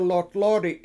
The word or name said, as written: 7lotlOOdi7